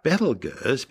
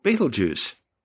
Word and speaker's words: Betelgeuse: Now, although I'm calling the star Betelgeuse because that's the most popular way astronomers pronounce its name, it's also commonly called Betelgeuse. But both Betelgeuse and Betelgeuse are actually tortured mispronunciations of its original Arabic name, Abteljazi, meaning the hand of the big man being Orion the Hunter.